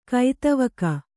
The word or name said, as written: ಕೈದೀವಿಗೆ